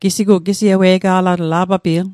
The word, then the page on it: ewe'ga'latl: Mi'gmaq